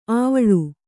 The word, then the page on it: ಆವಳಿ: āvaḷi; Kannada